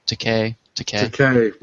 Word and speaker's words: Takei: Takei rhymes with okay.